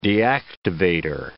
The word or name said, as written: deactivator